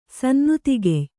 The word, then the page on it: ಸನ್ನುತಿಗೆಯ್: Kannada